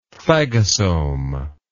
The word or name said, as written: phagosome